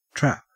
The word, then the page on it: trap: Australian English